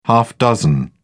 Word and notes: half dozen: British English

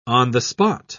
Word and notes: on-the-spot: British English

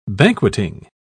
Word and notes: banqueting: British English

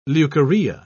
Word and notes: leucorrhea: British English

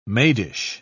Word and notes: maidish: British English

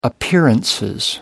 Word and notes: appearances: British English

